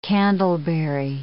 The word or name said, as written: candleberry